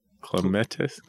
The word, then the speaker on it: clematis: C-L-E-M-A-T-I-S. – Clements. – Clematis. Oh, it's flower. It's flower. – A-T-I-S. Clematis.